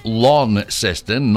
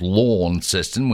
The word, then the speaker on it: Launceston: Yeah, that's Launceston, not Launceston. We know you're a mainlander if you pronounce it the other way.